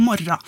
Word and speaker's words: morgen: Og så kan jo også Torgrim være litt sleip, og det at når han ser bokstavene M-O-R-G-E-N, så kan han godt uttale det som morra.